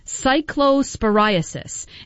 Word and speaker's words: cyclosporiasis: It's this outbreak of, and I'm going to do my best to pronounce this, cyclosporiasis.